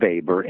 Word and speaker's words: Weber: It's spelled like Weber, W-E-B-E-R, but it's pronounced Weber. He was German.